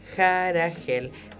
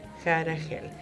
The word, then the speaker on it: Harahel: H-A-R-A-H-E-L. Jaragel. Las H se pronuncian como una J suave. Jaragel.